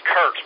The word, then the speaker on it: Kurt: Loving Common, my name is Kurt, spelled with a K.